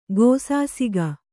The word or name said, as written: ಗೋಸಾಸಿಗ